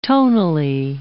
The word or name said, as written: tonally